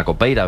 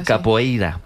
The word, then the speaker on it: capoeira: Pues todos sabemos lo que es la copeira, ¿verdad? – Capoeira.